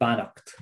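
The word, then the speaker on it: Beannacht: If anyone would like to check out something in particular, I'd recommend a poem called Bannocht. That's an Irish word for blessing. It's B-E-A-N-N-A-C-H-T is how it's spelled.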